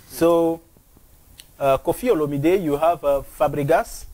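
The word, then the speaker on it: Olomidé: It's not Olamide. – It's Olomide. – It's Olomide. Kofi Olomide. – Okay. – Yeah. Don't pronounce it in English. Take it the way it is, man. – Like the way it is in the French.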